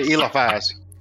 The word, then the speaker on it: Eliphaz: And I always mispronounce this, please. Eliphaz. And what is it? – Everybody pronounces it different ways. I'll say Eliphaz.